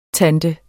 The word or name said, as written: tante